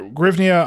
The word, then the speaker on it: hryvnia: In English, I think it's hryvnia. – Hryvnia is good, yeah. Hryvnia is very good, yeah.